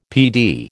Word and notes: PD: American English

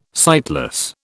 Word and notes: sightless: British English

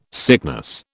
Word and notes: sickness: British English

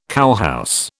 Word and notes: cowhouse: British English

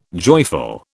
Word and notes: joyful: British English

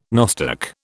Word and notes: nostoc: British English

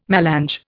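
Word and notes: mélange: Indian

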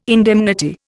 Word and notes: indemnity: Indian

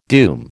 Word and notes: doom: American English

